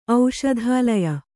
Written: ಔಷಧಾಲಯ

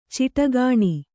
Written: ಚಿಟಗಾಣಿ

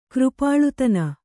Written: ಕೃಪಾಳುತನ

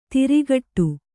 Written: ತುರುಕುಡಿ

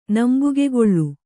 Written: ನಂಬುಗೆಗೇಳು

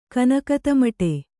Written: ಕನಕತಮಟೆ

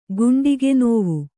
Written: ಗುಂಡಿಗೆನೋವು

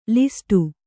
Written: ಲಿಸ್ಟು

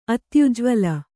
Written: ಅತ್ಯುಜ್ವಲ

